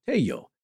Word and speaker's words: Tello: Now, he was a student of a man known as the father of Peruvian archaeology, Dr. Julio Cesar Tello. And that's T-E-L-L-O. So in Spanish, you would pronounce as a Y. Sound.